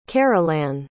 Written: carolan

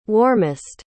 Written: warmest

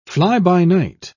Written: fly-by-night

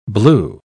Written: blew

